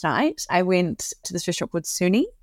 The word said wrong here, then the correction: Sune: I don't actually think that's how you pronounce it. I think it's better to pronounce like Suna.